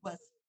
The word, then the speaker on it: Wasf: Guas, was lo dije bien. Guas, ya, W-A-S-F. Guas.